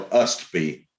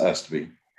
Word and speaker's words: Ustby: It's UTSBY. How do you pronounce Ustby. It? Utsby. – No, Ustby. – It's UST. – Oh, yeah. Okay. – Ustby. Alyssa Ustby.